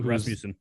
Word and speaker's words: Rasmussen: I never pronounce this guy's name right. Rasmussen. – Nope, Rasmussen.